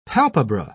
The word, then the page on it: palpebra: British English